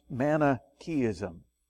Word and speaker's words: Manichaeism: This is pronounced Manichaeism. Manichaeism.